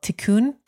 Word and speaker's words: tikkun: And you talk about, again, and I'm correct my pronunciation if it's wrong, this Kabbalistic tool is called tikkun. – Mm-hmm. That's right.